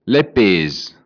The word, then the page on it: che fèta: Bergamasco